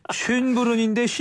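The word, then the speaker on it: Schönbrunn: 신년음악회가 비싼 티켓을 구입해 턱시도와 드레스를 입고 듣는 음악회라면 쉔베른 여름밤 음악회는 일반 대중을 대상으로 하는 무료 음악회입니다. – 전 쉰부터 갈 수 있는 줄 알고. – 이게 발음이 어렵습니다. 쉰부른인데 쉰이 될 수도 있어요. 네, 쉰부른. 네.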